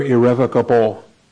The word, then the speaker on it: irrevocable: For the sake of the fathers, notice the gifts and the calling of God are irrevocable. Did I say that right? Irrevocable, irrevocable. Irrevocable, without repentance.